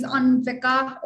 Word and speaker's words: Anvika: So in the US, like, people would just pronounce my name as Anvika or Becca.